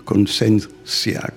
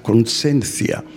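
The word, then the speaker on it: conciencia: αλλά πήρε μια άλλη διάσταση ε, ξέρω εγώ, στα ισπανικά είναι παρεμφερές, ας πούμε, λέγεται κονσένθια, κονσένθια, γιατί γράφεται με, με ζήτα που προφέρεται θου, θήτα στα ελληνικά.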